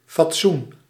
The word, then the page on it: forme: [fɔʁm]; France